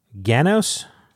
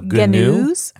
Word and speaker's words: Ganoes: So Theo said that he was going to pronounce it just like the word canoe, but with a G. So Gnu.